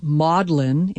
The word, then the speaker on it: Magdalene: The New Testament's Mary Magdalene, whose name is pronounced maudlin in Britain, has become an adjective meaning overly sentimental.